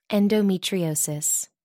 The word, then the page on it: endometriosis: American English